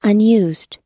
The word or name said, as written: unused